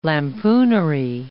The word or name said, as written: lampooner